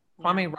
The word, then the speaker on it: Kwame: So they're saying state environmental officials are asking Attorney General, oh gosh, how do you pronounce this? Is it Kwame? – Kwame